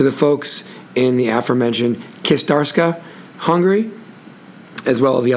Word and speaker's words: Kistarcsa: I'm going to say it phonetically. It's Kistarska. K-I-S-T-A-R-C-S-A.